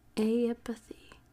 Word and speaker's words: aeipathy: A-E-I-P-A-T-H-Y. Aipathy? Who knows? Actually, here, there's a pronunciation. Aipathy. Okay, I was pretty far off.